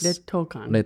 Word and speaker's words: 劣等感: 劣等感. – 劣等感.